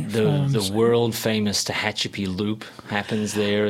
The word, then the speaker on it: Tehachapi: I never pronounced that town's name correctly. – Tehachapi? – Tehachapi, California. Yeah. – And I don't know whether I'm pronouncing it right. – That sounds right.